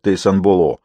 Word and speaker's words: Тейсанбалон: У них и Тейсанбалон произносится как Тейсанболо.